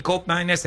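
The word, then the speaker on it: Koopmeiners: Qui parliamo fiammingo, che è l'olandese, per la cronaca colgo l'occasione per dire che di Cop Miners, la doppia O di Cop Miners invece si legge U. – Oh. – No, no, O si legge O, invece OE si legge U. Quindi Cop Miners è corretto chiamarlo Cop Miners come se ci fosse una.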